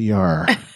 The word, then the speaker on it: Yar: Yes, Tasha Yar. But anyway, rhymes with tar.